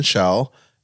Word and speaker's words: Schaal: Kristen Skull, I can't pronounce her last name. Scholl? – I've got it. Stand by. It's Kristen Schaal as number Schaal.